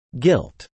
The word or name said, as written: guilt